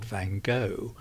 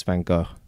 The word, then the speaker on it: Van Gogh: I'm afraid not, but it's very difficult to say correctly because the English find it almost impossible to pronounce the Dutch, which is something like Van Gogh, but even I can't do it properly. But it is complicated because in America he's called Van Gogh, and in France they all call him Van Gogh.